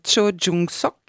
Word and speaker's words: Cho Jung-sok: Alors je vous propose qu'on écoute un grand chanteur, surtout un grand acteur, il s'appelle Cho Jung-sok. – Oui. – J'espère le prononcer comme il faut. – Ça va. – Bon, ça va.